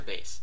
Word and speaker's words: Bass: No, how do you say the first word? – Ed. E-D. – No, not Ed. – Bass. – Bass or bass? – Bass. – Like a fish.